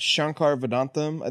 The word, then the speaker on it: Shankar Vedantam: Hidden Brain, is that Shankar Vedantam? I think is how you say his name. – Yeah. Shankar.